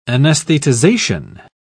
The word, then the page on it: anaesthetization: British English